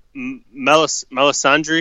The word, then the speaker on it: Melisandre: So Melisandre, am I saying that right? – I think so.